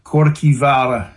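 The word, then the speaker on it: Kirkjuvágr: It's actually pronounced Korkivara in Old Norse.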